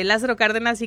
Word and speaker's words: Lázaro Cárdenas: Así que bueno, sí, me dice que sí lo dije bien, claro, sí, es Lázaro Cárdenas y Carranza cuando agarras justo esa desviación.